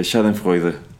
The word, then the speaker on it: Schadenfreude: Entonces como que es un, una es un schadenfreude fraude, diría Lisa. A ver, ¿usted cómo se pronuncia Usted que sí, sí se habla alemán. Ah, es este Schadenfreude. Schadenfreude.